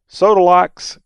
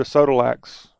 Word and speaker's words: Sodolak's: Sodolak's Original Country Inn. I think is how it's pronounced. S O D O L A K apostrophe S.